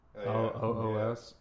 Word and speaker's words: Loos: I think it's pronounced Adolf Loos. Loos.